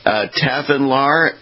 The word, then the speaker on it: Tafinlar: Tafinlar and McKinnist, is that correct? – That's correct.